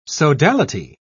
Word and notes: sodality: British English

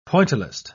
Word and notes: pointillist: British English

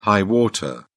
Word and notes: high water: British English

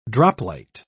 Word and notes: droplight: British English

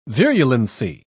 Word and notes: virulency: British English

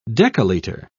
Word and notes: decaliter: British English

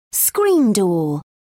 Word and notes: screen door: British English

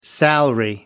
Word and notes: salary: American English

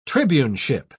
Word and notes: tribuneship: British English

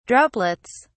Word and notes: droplets: British English

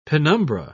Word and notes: penumbra: British English

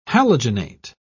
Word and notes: halogenate: British English

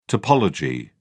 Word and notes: topology: British English

